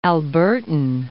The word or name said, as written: Albertan